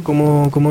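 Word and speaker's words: Qom: Él es Oscar Talero, como decíamos, y es dirigente de la comunidad COM, como él bien me dijo recién que se pronuncia.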